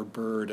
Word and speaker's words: beard: Then a second quatrain, C, D, leaves, herd, sheaves, beard, or bird, as it would have been pronounced then.